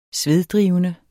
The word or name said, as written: sveddrivende